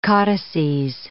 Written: caudices

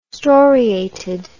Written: storiated

